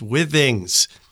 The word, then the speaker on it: Withings: It has lasted a very long time, and it gives me the opportunity to let everybody know that it's pronounced Withings, because not one podcaster in my feed pronounces this right.